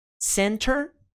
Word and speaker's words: center: Center é pronunciado por americanos muitas vezes. Isso é opcional. Center, center. Por que que é pronunciado? Justamente por esse padrão. Uma sílaba começada com T e logo antes desse T tem N. Então, eles não pronunciam o T e pronunciam somente o N. Center vira center.